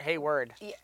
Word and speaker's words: Hayward: So this whole time, there's been a bunch of theories on Hayward. Did I say it right? Hayward? – Hayward. Yeah, like W-A-R-D.